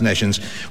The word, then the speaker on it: nescience: Yeah, spelled N E S C I E N C E, and it's pronounced Neshens, which is a kind of a glow, isn't it?